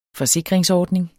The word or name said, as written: forsikringsordning